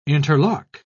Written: interlock

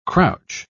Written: crouch

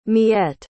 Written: miette

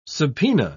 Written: subpoena

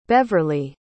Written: Beverlee

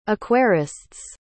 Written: aquarists

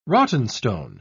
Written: rottenstone

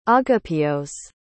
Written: agapios

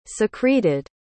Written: secreted

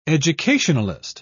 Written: educationalist

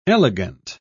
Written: elegant

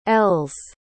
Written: Ells

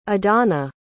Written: idana

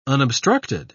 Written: unobstructed

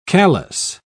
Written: callus

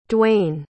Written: duayne

